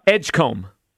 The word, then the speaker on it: Edgecombe: Edgecombe. – Edgecomber.